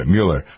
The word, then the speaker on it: Mueller: Mueller. I always remember the Mueller's Egg Noodles. That's how I pronounce this guy's name.